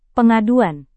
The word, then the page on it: pengaduan: Indonesian